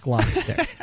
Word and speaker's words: Gloucester: Gloucester has an H, Gloucester. – Oh, really? – But it's pronounced Gloucester, or Gloucester if you're from Massachusetts.